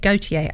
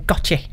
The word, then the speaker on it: Gotye: I only learned how to pronounce that the other day. Gotye. I always just used to call him Gotye.